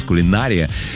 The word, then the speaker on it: кулинария: Можно говорить кулинария и кулинария, но рекомендуется все же ставить ударение на «и», так, как вы, собственно, и сказали.